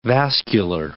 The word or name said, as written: vascular